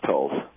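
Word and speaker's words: Toles: Yeah, I think it's Tolles. – For whom the bridge tolls, he's brilliant.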